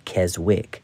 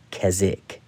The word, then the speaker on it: Keswick: Most Americans pronounce that Keswick, but the W is silent. Keswick.